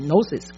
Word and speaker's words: gnosis: 数学都会用希腊文的很多的字母来表示嘛，那这个它是一个合成词，就是 epi 加 gnosis， gnosis 就是知识，就是关于上帝的知识这个就是 agnostic 或者 knowledge，其实是英文的 knowledge 是从拉丁文过来的，拉丁文其实应该是从希腊文过来的，所以呢 knowledge gnosis gnosis gnosis gnosis g 可以不发音，所以 gnosis gnosis knowledge，然后呢 epignosis。